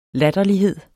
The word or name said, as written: latterlighed